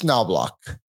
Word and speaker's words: Knoblauch: Knoblauch. – Chris Knoblauch. – I play hockey with a guy named Greg Knoblauch.